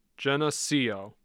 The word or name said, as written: Geneseo